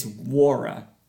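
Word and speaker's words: Nwora: Now we add Jordan Wara, who was the – I said Nora. It's Wara. The N is silent.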